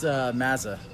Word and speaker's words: Mazza: Yeah, it's Mazza, actually. – Not like a Mazda car.